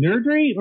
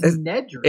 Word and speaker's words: Nedry: So, is it Nerdry or is it Nedry? – I think it's Nedry.